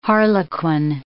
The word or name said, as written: harlequin